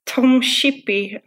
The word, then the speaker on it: Tom Shippey: And Tom Shippey, I'm not sure if I pronounced that correctly. – That's right.